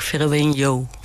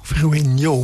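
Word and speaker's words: Verueño: Nou, er zit op de N een tilde, zo'n kringeltje. En daardoor is de uitspraak Verueno. – Verueno, met een J erin.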